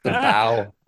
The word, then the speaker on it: bough: B-O-U-G-H. Appendage. How do you pronounce that, appendage. B-O-U-G-H. The bow.